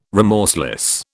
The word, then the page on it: remorseless: British English